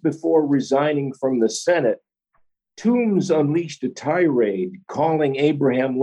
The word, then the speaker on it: Toombs: That's spelled T-O-O-M-B-S, Toombs.